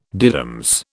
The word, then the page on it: diddums: British English